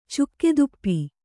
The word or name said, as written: ಚುಕ್ಕೆದುಪ್ಪಿ